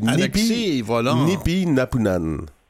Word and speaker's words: Nipinapunan: oui, Nipi, Napunan. – Est-ce que je le prononce bien, Alexis? – Oui. oui, oui, oui, absolument.